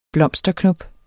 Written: blomsterknop

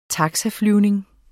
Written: taxaflyvning

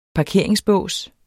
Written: parkeringsbås

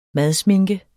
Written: madsminke

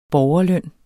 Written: borgerløn

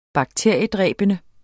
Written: bakteriedræbende

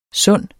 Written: sunde

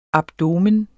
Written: abdomen

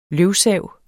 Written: løvsav

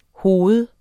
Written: hoved

